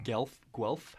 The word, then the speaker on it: Guelph: Oh yeah, this is a local artist who's gonna do a show here on the 23rd from Guelph. How do you say Guelph. – That? – Guelph. Guelph, yeah. Ontario.